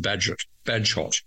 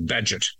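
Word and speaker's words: Bagehot: His name is Baggehot, and I thought it was Badgehot. It's actually Badgett.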